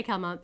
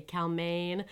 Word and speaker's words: Calment: We're going to say Calment because that's how it's spelled. In the South, there's kind of like a region. I think a lot of people where she's from say Calmaine.